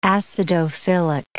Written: acidophilic

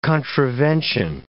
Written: contravention

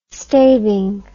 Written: staving

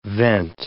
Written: vent

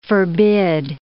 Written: forbid

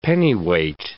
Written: pennyweight

